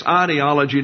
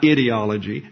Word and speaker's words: ideology: The problem that we face here is an ideology, and by the way, it's ideology, not ideology. No dictionary has ideology as a pronunciation. That's the sophisticates in the Northeast that didn't want the long I.